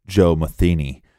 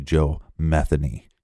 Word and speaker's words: Metheny: They're spelled the same way. And if you look up some reports, they will say Joe Metheny, right. But it's actually Joe Metheny. – Yeah, we got that from local Baltimore reports. So him being from Baltimore, we're going with that pronunciation.